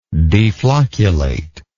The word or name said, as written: deflocculate